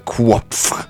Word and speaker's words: Qoph: De hedder Kvopf. Q-O-P-H.